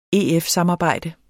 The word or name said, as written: EF-samarbejde